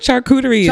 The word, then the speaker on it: charcuterie: How do you say it? Charcuterie. That's the word.